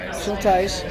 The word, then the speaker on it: Schultheis: So it's Schultheis. Schultheis, and that's spelled E-I, not I-E. – Correct. – Okay. – Because that's the German.